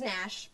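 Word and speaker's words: Gnash: His name is Nash, by the way, but with a G, because the G is silent.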